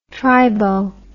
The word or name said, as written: tribo